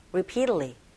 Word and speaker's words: repeatedly: 특히나 repeatedly, 이 부분은 이 부분에 강세 주시고 T 사운드가 모음사에 껴있네요. 네, 플랩 현상에 의해서 T 사운드가 R 소리에 각각이 들려져서 여러분들이 들리는 소리, 또 여러분들이 발음하는 소리가 repeatedly, repeatedly 이렇게 하시면 되겠고 going through 하실 때 입모양 잡아주시면 되겠죠.